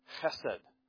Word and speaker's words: chesed: This idea of mercy is a Hebrew word that can be proclaimed or pronounced as chesed. Got to get that kind of worked up in the back of your throat. Chesed.